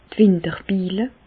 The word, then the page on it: d' Winterbille: Alsatian; Bas-Rhin; Reichshoffen